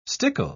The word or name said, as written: stickle